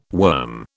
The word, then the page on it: worm: British English